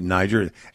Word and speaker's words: Niger: In Niger, they pronounce it Niger. They're a former French colony. So we pronounce it Niger.